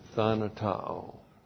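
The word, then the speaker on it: thanatao (θανατόω): All right, and then we have the verb, thanatao. T-H-A-N-A-T-O, long O. Thanatao.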